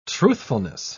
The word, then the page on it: truthfulness: British English